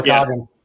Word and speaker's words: Namekagon: I always say it wrong because I've not been there enough. Nama Namekagon people. Nama, Namekagon. – Yeah, yeah, right. Namekagon Barrens.